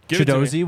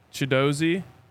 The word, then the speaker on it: Chidozie: You changed up the pronunciation on me. – I'm on the Houston website and I see it right in front of me. – Okay. – Chidozie Nwankwo. – Chidozie Nwankwo. – Nwankwo. – Yes.